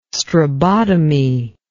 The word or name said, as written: strabotomy